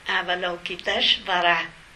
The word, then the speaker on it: Avalokiteshvara: Chứ cái tiếng thật của nó là chữ S có dấu sắc. Nhưng mà đây âm ra là chữ S, chữ H. Tại vì chữ S có dấu sắc đọc là chữ S. Cho nên âm ra cho dễ là Avalokiteshvara.